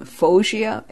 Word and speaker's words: Foggia: And she was destined to finish in, now you have to help me pronounce this name, Foggia, F-O-G-G-I-A.